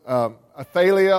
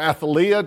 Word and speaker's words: Athaliah: Her name was Athaliah or Athaliah, depending on which pronunciation guide you follow.